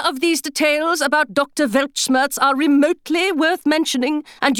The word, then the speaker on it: Weltschmerz: As the polyglots among you are doubtless already aware, the German Weltschmerz is pronounced Weltschmerz, and it means...